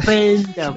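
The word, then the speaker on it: Pænda: Elle s'appelle Panda, mais c'est écrit A-E-P-A-E-N-D-A. Alors, comment est-ce que ça se prononce? – Panda, voilà.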